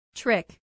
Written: trick

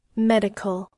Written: medical

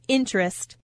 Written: interest

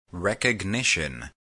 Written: recognition

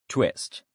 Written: twist

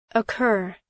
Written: occur

